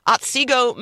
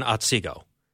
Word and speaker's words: Otsego: Suzanne from Otsego, Minnesota. I don't know. Am I supposed to? Is the T silent? – No. That's right. You said it right. I grew up in Otsego.